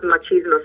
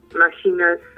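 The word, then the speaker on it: Mattachine: it's not Machismo Society, it's the Machina Society.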